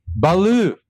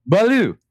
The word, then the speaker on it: Baloo: Baloo. – Yeah, blue. – Like the color. Baloo.